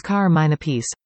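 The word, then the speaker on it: Carmine Appice: Apice, Apice, depending on who you believe. – Carbon? Really Hollywood? It's Carmine Apice. Just ask him. That's how he pronounces it.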